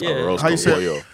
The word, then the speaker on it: arroz con pollo: That's arroz con pollo.